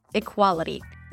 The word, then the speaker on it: equality: Equality. Equality has four syllables. We begin with I as in sit. I. So it's not I, equality, but I. To make this sound, you want to drop your jaw a bit.